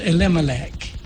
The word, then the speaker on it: Elimelech: The story begins whenever I'll pronounce his name, Eli Malek, but it's pronounced Elimelech.